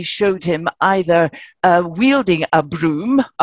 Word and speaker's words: Brougham: Now, Broom, he's called Broom. Nobody knows how to pronounce his name. It's spelt B-R-O-U-G-H-A-M, but we know for sure that it was pronounced Broom.